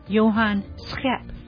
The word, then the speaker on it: Schep: Now that is the English version of the pronunciation. It's spelled S as in Sam, C-H-E-P as in Peter. Doesn't look too hard, but he would say Johan Schep.